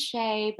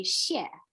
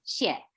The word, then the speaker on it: Xie: I was just like, oh, my last name is Shay, but it's really not pronounced that way. And okay. So it's actually Xie. We speak Mandarin at home with my parents. I grew up speaking Mandarin at home with my parents. And so the proper way to say it is Xie.